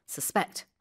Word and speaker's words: suspect: The stress is on the second syllable, suspect.